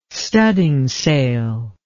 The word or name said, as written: studdingsail